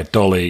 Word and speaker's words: DALL-E: I'm making lists for the move to Portugal, and you had given me, I think it was, it's called, is it pronounced Dolly? Dolly, D-A-L-L-E. – Yeah, Dolly, yeah, yeah. In fact, before this show, I did ask ChatGPT how D-A-L-L-E is pronounced, and it said Dolly.